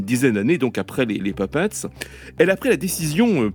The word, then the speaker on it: Pipettes: Ça s'écrit pipette, hein, au pluriel, comme des pipettes en chimie, mais ça se prononce en anglais comme Puppets, donc poupée.